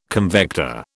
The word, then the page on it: convector: British English